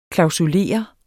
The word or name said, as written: klausulere